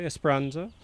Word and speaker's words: Esperanza: Title track from the new album, Esperanza, which I'm not pronouncing very well, but I'm getting close to it. Esperanza.